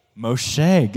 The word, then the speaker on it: Moshe: Does anyone remember how to say his name in Hebrew? Moshe. We got Moshe. Everyone say Moshe. It's just a fun, I think it sounds cooler than Moses, personally.